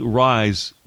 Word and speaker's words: Ries: By the way, Lora Ries, it's spelled like it's Rise, but it's Ries with the Heritage Foundation.